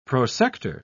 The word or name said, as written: prosector